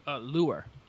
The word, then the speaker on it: Leuer: Is that how you say it? Am I saying that right? – Lure. – Lure, okay. L-E-U-E-R, the guy from the Phoenix Suns who, you know, a lot of you have been playing.